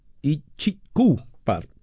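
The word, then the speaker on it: Itchycoo Park: Ichito Park, Ichiku Park, perdón que lo dije mal,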